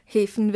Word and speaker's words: Häfen: Häfen wird man sagen. – Häfen, okay. – Also mehr wie die Hefe.